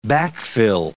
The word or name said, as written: backfill